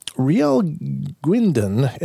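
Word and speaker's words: Rheal Guindon: And this says, Riel Gwinden, and Riel is spelled R-H-E-A-L, and the last name G-U-I-N-D-O-N, of Ontario, Canada, was on a camping trip with his parents in when they decided to go to the boats to go fishing.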